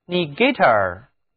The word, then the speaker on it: negator: 请注意在单词 negative 中，字母 NE 组合读作 ne ne negative，重音在开头，而到了 negator 这个词里面， NE 组合却读作 ni ni，重音也后移到了第二个音节上，就是 negator， negative and negator。